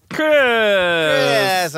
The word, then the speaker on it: Christmas: All right, shout out to, you know, the T that is silent in Christmas that we all forget about all the time.